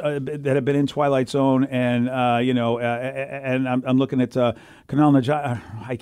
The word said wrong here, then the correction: Kumail Nanjiani: Kumail Nanjiani.